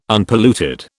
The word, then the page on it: Unpolluted: British English